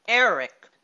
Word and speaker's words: Erech: Now, Ur is spelled U-R-U-K. Now, the cyclopedia tells us that Ur is pronounced differently in Hebrew. It's pronounced E-R-E-C-H, Eric.